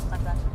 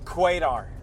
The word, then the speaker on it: Qatar: How would you pronounce Qatar? – Qatar and Qatar. الخطوط الجوية القطرية. Qatar. – What was the funniest pronunciation you've heard? – Quadar. – No, you're joking.